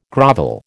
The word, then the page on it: grovel: American English